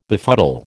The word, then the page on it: befuddle: American English